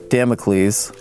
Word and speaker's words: Damocles: While the group discussed Thoreau's exchange being living under a veritable sword of Damocles. – Good mention. It's a reference. – Yeah, I know. Did I say it right? – You did. I think so, yeah.